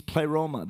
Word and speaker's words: pleroma: If you look at it in the Greek, it means pleroma. That's how you say it. Everyone say pleroma.